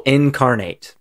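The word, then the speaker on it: Inkarnate: it's Incarnate, but it's spelled INK, like ink, and then Incarnate.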